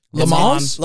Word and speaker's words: Le Mans: Le Mans. 24 Hour Le Mans is an endurance race. – It's legendary. – There's an S at the end. Don't pronounce.